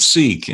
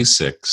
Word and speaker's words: Sikh: I've always said Sikh, and I hear you say Sikh, so getting it wrong.